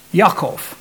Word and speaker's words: Yaakov: Jacob, Yaakov, that's the way the Hebrew word is pronounced.